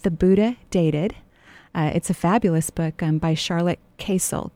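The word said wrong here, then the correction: Kasl: K-A-S-L. I'm not sure if that's the correct pronunciation. And it's a handbook for finding love on a spiritual path. – So I think it's actually Castle.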